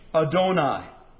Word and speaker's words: Adonai: The third term is Adonai, or as it is commonly pronounced in English, Adonai.